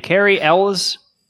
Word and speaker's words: Cary Elwes: Cary Elwes.